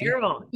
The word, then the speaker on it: Terrell: Am I saying this right? Is it Terrell ISD? – Terrell, you got it.